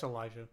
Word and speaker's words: Alijah: I think it's Elijah, but it's spelled Alijah. – Yeah, that's Elijah. – Yeah, it's just a different spelling of Elijah. Yeah. – Instead of an E, it's an A.